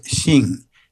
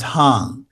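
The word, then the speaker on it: Xing (行): The character I'm talking about is pronounced Xing. It's actually originally a pictograph of a street intersection. But it's also pronounced Hang. One pronunciation is the southern pronunciation. The other is the northern pronunciation.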